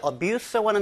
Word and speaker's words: abuse: 보통 이제 abuse라고 하는 단어가 명사나 동사로 쓰이는데요. 물론 동사로 쓸 땐 abuse, 그리고 명사로 쓸 땐 abuse. 이렇게 발음이 좀 차이가 나긴 하겠습니다만 그게 세 가지 종류를 생각하시면 되겠습니다.